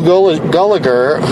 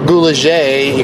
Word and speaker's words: Gulager: It stars Clu Gulager, or Gulager, you can pronounce it anyway, several different ways.